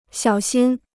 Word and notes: 小心: xiǎo xīn

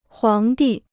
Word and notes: 皇帝: huáng dì

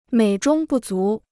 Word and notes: 美中不足: měi zhōng bù zú

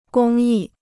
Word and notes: 公益: gōng yì

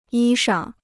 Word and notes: 衣裳: yī shang

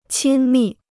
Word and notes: 亲密: qīn mì

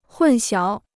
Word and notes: 混淆: hùn xiáo

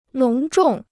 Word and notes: 隆重: lóng zhòng

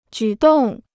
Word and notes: 举动: jǔ dòng

